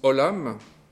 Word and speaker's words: olam: Ça se prononce olam.